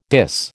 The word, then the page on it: dis: American English